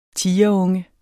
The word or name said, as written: tigerunge